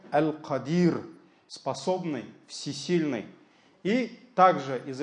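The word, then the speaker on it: Аль-Кадир (القادر): Первое имя из его имен – Аль-Кадир. Кадир – ударение, то есть после «каф» идет «алиф», «мед». Да? Кадир, Аль-Кадир.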